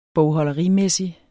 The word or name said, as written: bogholderimæssig